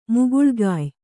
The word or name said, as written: ಮುಗುಳ್ಗಾಯ್